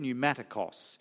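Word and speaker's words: pneumatikos (πνευματικός): That's how you pronounce that word, pneumatikos.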